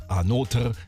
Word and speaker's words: Anotr: O-T-R am Ende. Macht zusammen Anotr, wie sie es aussprechen, Another Talk to You für euch jetzt hier in der Abendshow.